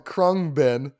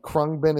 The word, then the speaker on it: Khruangbin: It is by a group called Krungbin.